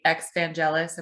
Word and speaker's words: Exvangelis: So Well, we kind of just wanted to get started with asking about what brought you to create Exvangelis.